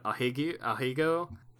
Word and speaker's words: ahegao: How do you pronounce that? Ahego? – I think you got it.